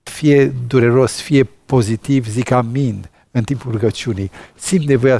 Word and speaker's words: amin: Amin nu este altceva decât preluarea și ajustarea vechiului amen în greaca modernă, amin, că eta era e, dar acum este i în greaca modernă.